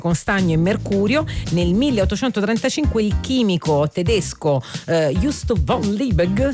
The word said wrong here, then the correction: Justus von Liebig: si pronuncerà così? Aiuto, voglio un aiuto dal pubblico. Vai, aiutami tu. – Justus von Liebig.